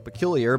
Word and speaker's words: Pqlyr: Their name is Pqlyr, and it's pronounced just like Pqlyr, but it's written P-Q-L-Y-R. Again, P-Q-L-Y-R, but it's pronounced Pqlyr.